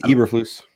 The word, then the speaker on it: Eberflus: We also had the Bears make their signing with Matt Eberflus. I don't know if that's pronounced right. – I think it's Eberflus. – Eberflus. Eberflus. That sounds much better.